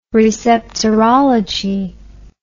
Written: receptorology

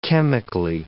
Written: chemically

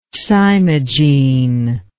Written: cymogene

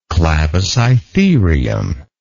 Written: clavicytherium

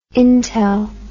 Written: intel

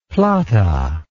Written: plata

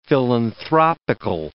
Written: philanthropical

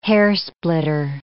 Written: hairsplitter

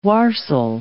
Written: warsle

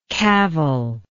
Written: cavilingly

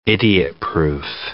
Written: idiot proof

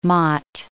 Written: Mott

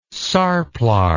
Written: sarplar

